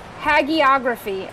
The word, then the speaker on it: hagiography: Let me I just said it. Hygiography. – Hagiography, according to Google.